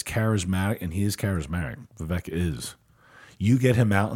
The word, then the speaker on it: Vivek: So, you know, Vivek rhymes with cake.